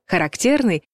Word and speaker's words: характерный: От разницы в значениях порой зависит, на какой слог падает ударение. Характерный — значит типичный, свойственный кому-либо или чему-либо.